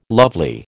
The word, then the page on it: lovely: American English